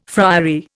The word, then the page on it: friary: Indian